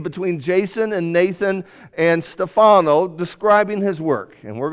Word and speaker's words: Stefano: A video discussion with Stefano. That's how you say it in Italy. Stefano. I knew him as Stefano.